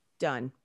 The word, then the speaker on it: Dunne: Dunne.